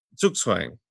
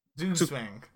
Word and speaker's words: Zugzwang: Zugzwang. – Zugzwang.